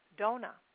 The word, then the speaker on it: Dana: And then for the latter part of next week, we have the goddess Dona. And it's spelled D-A-N-A, like Dana, but it's pronounced Dona.